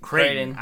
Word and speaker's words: Creighton: is it Creighton? How do you pronounce it? – Creighton.